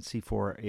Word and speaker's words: CIFOR: And C4 is an acronym, and it's spelled C-I-F-O-R.